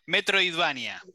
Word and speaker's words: Metroidvania: nunca sé pronunciarlo bien, Metro, eh, Metroidvania, metro, Metroidvania. – Metroidvania, eso, Metroidvania, que son estos juegos de, eh, en 2D donde vas recorriendo eh, mapas, básicamente.